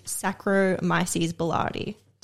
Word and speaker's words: Saccharomyces boulardii: and they also used Saccharomyces boulardii.